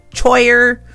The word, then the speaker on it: choir: C-H-O-I-R, choir. The boys' choir performs tonight. Choir. They have a very big choir. It looks like the C-H should be pronounced choir, but it's choir.